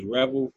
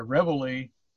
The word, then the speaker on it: reveille: It's spelled R-E-V-E-I-L-L-E. So it's Revel for Radicals. – Well, I would pronounce it Reveille. – Okay. I'll take your pronunciation over mine. – The reason I say that is because in the military, Reveille is a bugle call to get up.